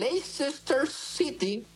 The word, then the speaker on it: Leicester: Vámonos hasta Inglaterra, donde el Leicester City. ¿Sí lo dije bien? – Sí.